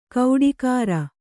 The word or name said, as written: ಕೌಡಿಕಾರ